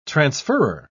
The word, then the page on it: transferrer: British English